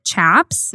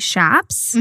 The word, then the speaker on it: chaps: So I've always pronounced it Chaps. – Mm-hmm. – But she ended up saying that it's actually Shaps, mm-hmm. – which I think it sounds like there's two different ways to say it. We did a little bit more research into it. – Yeah. – But just, it sounds like in the Southwest region of the U.S., they pronounce it Shaps, mm-hmm.